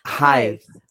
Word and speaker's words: Hithe: Hyde. – It pronounced like tithe, but with an H instead. H-I-T-H-E.